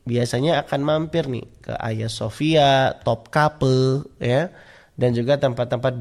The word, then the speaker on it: Hagia Sophia: Kalau ditulisannya Hagia Sophia, tapi bacanya Hagia Sophia, adik-adik ya.